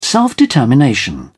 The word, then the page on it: self-determination: British English